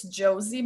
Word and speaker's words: Josi: It's Josie. My name is spelled J-O-S-I, Mara, M-A-R-A, Richards with S at the end.